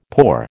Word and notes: pour: American English

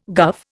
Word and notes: guv: Indian

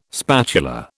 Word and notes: spatula: British English

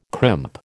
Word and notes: crimp: American English

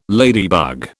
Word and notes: ladybug: British English

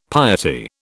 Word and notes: piety: British English

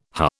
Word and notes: huh: British English

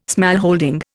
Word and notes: smallholding: Indian

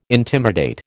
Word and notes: intimidate: American English

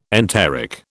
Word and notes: enteric: British English

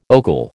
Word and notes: ogle: American English

